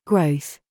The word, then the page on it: gig: British English